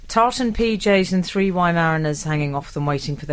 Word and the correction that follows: Weimaraners: Three Weimaraners.